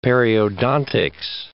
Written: periodontics

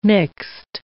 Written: mixed

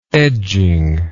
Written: edgingly

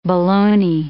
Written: bologna

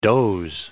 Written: doze

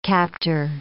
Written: captor

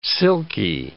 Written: silky

